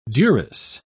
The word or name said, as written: duress